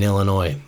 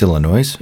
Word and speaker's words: Illinois: I was like, Well, thanks, Illinois. – Yeah. Right back at you, Illinois. – Oh, the S is silent.